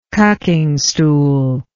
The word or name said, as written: cucking stool